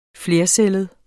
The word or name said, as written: flercellet